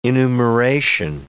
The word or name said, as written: enumeration